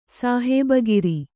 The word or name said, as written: ಸಾಹೇಬಗಿರಿ